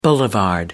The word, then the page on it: boulevard: American English